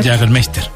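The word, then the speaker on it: Jägermeister: Jagermeister.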